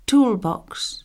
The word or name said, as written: tool box